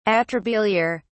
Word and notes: atrabiliar: British English

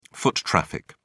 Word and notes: foot traffic: British English

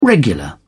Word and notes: regular: British English

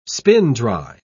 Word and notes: spin-dry: British English

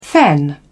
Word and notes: fen: British English

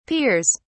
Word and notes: peers: British English

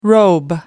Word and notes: robe: American English

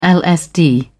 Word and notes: lsd: British English

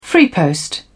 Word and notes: freepost: British English

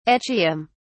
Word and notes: echium: British English

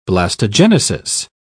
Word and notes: blastogenesis: British English

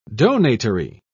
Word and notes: donatory: British English

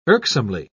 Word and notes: irksomely: British English

